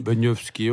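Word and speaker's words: Benyovszky: Alors comment on prononce Benjovski? – Benjovski, on dit Benjovski.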